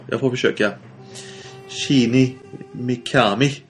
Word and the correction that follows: Shinji Mikami: Shinji Mikami.